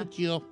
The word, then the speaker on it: Puccio: Si quieres pronunciarlo en italiano, Puccio. O Puccio.